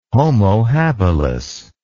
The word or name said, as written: homo habilis